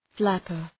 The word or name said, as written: flapper